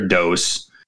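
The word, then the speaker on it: D.O.S.: So, it's spelled with the little periods as like initials, but it's Pegs Good Rarer DOS, Rarer Dose.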